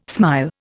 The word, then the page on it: smile: Indian